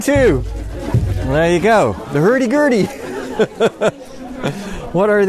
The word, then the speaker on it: hurdy-gurdy: Drums and guitars, and these are violins, or it's a Gurdy. – Hurdy-gurdy. – Hurdy-gurdy. – Yes. Perfect pronunciation.